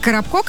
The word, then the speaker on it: Коробко: Нет, Коробко, конечно, но ударение как у Коробки.